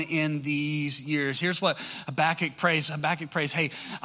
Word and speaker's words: Habakkuk: You got to say it like Habakkuk, you know, like you got tobacco in your mouth, Habakkuk, you know.